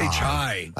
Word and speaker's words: Chi: Is it Chai or Chi McBride? C-H-I. – I say Chai.